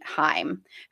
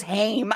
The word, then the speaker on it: Haim: I thought it was Haim.